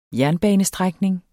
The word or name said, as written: jernbanestrækning